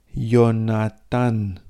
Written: ionatan

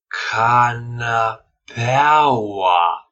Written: canapeaua

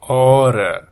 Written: ora

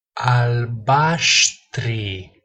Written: albastri

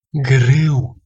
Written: grau

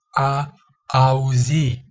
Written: a auzi

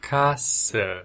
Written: casa

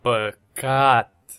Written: pacat

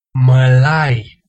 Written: malai

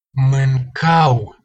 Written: mancau

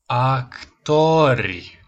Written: actori

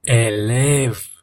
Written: elev

